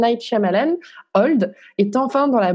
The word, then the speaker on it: Shyamalan: Shyamalan. – Shyamalan.